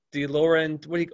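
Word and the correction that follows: De Laurentiis: how do you say that? DeLauren? – No, it's DeLaurentis.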